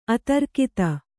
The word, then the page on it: ಅತಿರಾಗತೆ: ati rāgate